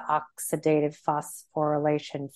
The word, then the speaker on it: oxidative phosphorylation: But in that state of the tearing down while you're working out, getting to a point of fatigue, you're probably leaving the oxidative phosphorylation phase. Did I say that right? – You said it right.